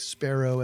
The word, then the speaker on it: Spero: Sparrow as in the Latin word for hope. Not sparrow as in the bird. So it's spelled S-P-E-R-O.